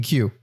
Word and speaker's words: Kew: Wait, Quay? Spell that. – K-E-W? – Oh, you mean Q? – No, K. – K-E-W? – Yeah. – Q. No, that spells Q. K-E-W?